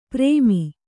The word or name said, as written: ಪ್ರೇಮಿ